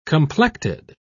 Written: complected

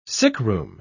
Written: sickroom

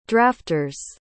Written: drafters

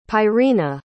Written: pyrena

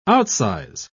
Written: outsize

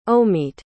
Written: Omeet

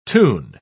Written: toon

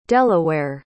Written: delaware

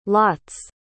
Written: lot's

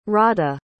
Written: radha

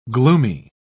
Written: gloomy

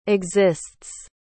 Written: exists

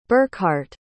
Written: burkhard

